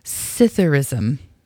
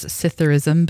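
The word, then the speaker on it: psithurism: Here is your word of the day, Sitherism. It kind of sounds like something that would be from Star Wars, Sitherism, but it's not. And it's spelled funny. It's P-S-I-T-H-U-R-I-S-M.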